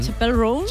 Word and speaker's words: Chappell Roan: Chappell Roan. – Chappell Roan, ¿no? – Ella misma nos explica cómo se pronuncia. – Chappell. Yes. Roan. Chappell Roan. Okay. – Ah, pues lo digo mal yo.